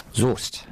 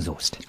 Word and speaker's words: Soest: Soest. Westfalen. – Wird das nicht mit S-O-E geschrieben? – Richtig, aber ausgesprochen Soest.